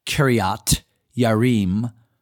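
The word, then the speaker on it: Kiriath-Jearim: But there was a man that prophesied in the name of the Lord, Uriah, the son of Shemaiah, of Kirjath-Jearim, or better pronounced, Kiriath-Yearim.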